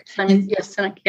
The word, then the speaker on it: Sinek: Simon Sinek, I think it's pronounced Sinek, it might be Sinek. – Sinek, yeah.